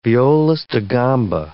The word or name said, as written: violist da gamba